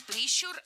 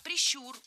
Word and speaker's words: прищур: Не прищур, а прищур. Ударение в этом слове ставим на второй слог. Да там его и оставляем во всех формах. Только прищур, прищура, прищуре.